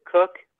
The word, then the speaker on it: Koch: Her last name is, she pronounces it cook, but it's spelled K-O-C-H. – Oh, wow. K-O-C-H. See, I would pronounce that koch if I saw it. I mean, I wouldn't pronounce it. She does not like it pronounced that way.